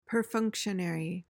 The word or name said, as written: perfunctionary